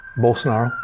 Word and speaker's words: Bolsonaro: So what happens when Jair Bolsonaro, Brazil. – Brazil, yeah. – How do you say his name? – Bolsonaro.